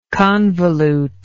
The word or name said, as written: convolute